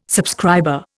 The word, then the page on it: subscriber: Indian